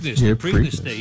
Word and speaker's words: Preakness: The Preakness? Preakness? – The Preakness.